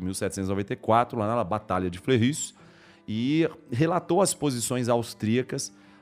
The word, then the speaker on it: Fleurus: A Batalha de Fleurus, ela pronuncia assim, ó, é, é Fleurus, Fleurus.